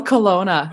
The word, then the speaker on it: Kelowna: And when I was doing my research on you, you're from a place in, I'm going to butcher the name, but it's Kelowna. I don't know. – Oh, so close. Kelowna. It's called Kelowna.